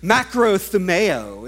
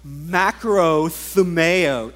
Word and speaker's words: Makrothumeo: Makrothumeo is how you say it in Greek. You guys want to try? Makrothumeo.